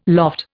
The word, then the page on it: loft: Indian